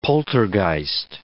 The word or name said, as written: poltergeist